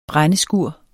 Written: brændeskur

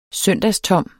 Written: søndagstom

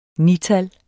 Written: nital

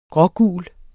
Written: grågul

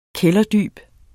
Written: kælderdyb

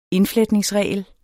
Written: indfletningsregel

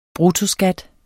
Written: bruttoskat

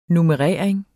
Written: nummerering

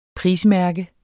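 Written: PRISMÆRKE

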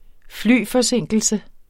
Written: flyforsinkelse

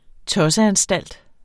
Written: tosseanstalt

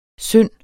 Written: synd

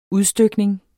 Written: UDSTYKNING

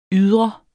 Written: YDRER

